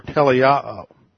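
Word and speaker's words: teleio (τελειόω): It's a crucial word in the New Testament, and it's the word teleio. T-E-L-E-I-O-O. T-E-L-E-I-O-O. Second O is a long O. Teleio.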